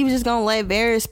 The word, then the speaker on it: Varys: Varys? – Yeah, that's his name.